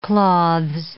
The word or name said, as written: cloths